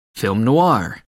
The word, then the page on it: film noir: American English